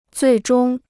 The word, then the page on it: 最终: zuì zhōng